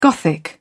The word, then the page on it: gothic: British English